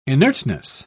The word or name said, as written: inertness